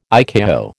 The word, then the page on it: ICAO: American English